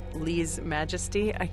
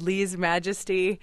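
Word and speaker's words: Lese Majesty: You got to have confidence. You said it right. It's all good. – The forthcoming album is called Lee's Majesty.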